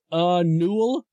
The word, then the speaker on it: Yniwl: It's pronounced, Newell. It's spelled Y-N-I-W-L, where they do surf music.